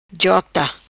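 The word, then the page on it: Jota: patua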